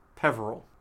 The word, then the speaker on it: Peverell: But Hermione also finds a grave with the symbol of Grindelwald on it with the name Ignatius Peverell.